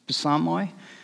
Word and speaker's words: Psalmoi: Now, you notice in the Greek, we actually pronounce the P, Psalmoi. We've kind of dropped it in the English, so it's just Psalms. You may have also heard the title Psalter.